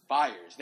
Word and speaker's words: Fiers: But the whistleblower, Fiers, how do you pronounce his name? – Fiers, there we go.